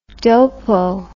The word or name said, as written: dopoe